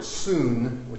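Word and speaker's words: soon (συν): It would be pronounced, you know, soon. Soon, which is a Greek preposition meaning together.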